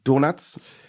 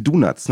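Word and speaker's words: Donots: Die Mitglieder mögen Sum 41, Blink 182, Itchy und die Donuts. – Ja, können wir alle sehr gut leiden, ne? – Und ich habe es falsch ausgesprochen, das sind die Donuts, ne? – Die Donuts, ja.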